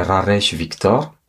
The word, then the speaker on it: Rares Victor: Bon, alors, je veux prononcer mon nom selon la façon comme on pourrait l'entendre, comment je l'entendrais au mieux. Donc, je dirais Rares Victor.